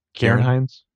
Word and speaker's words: Ciarán Hinds: I mean, it's like for 20 seconds, I thought maybe it's, how do you pronounce his name? Ciarán Hinds. Is that right? Ciarán Hinds. Mm-hmm.